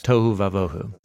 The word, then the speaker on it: tohu vavohu: Tohu vavohu.